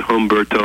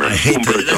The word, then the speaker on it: Humberto: You can call it Humberto or Umberto.